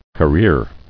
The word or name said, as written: Careering